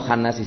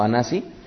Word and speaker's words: Hanasi: Es el príncipe, o Hanasi sería más bien la pronunciación correcta, no Hanasi, sino Hanasi.